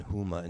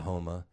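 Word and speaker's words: Houma: I was hired to read lines with Kevin Costner to help him pronounce things like he was saying Huma instead of Homa.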